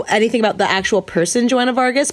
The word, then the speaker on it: Joanna Vargas: Joanna Vargas. Or is that, am I saying it right? – Yes. – I feel like Joanna Vargas is someone I went to high school with.